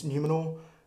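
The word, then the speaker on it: Númenor: The fall of, and I'm going to pronounce this wrong, people can hate me, I think it's Numenor, which was another... – I think you got that right.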